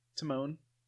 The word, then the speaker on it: Timon: Obviously, it's Timon, as in Timon and Pumbaa, but...